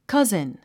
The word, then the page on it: cousin: kʌ’zn; カズン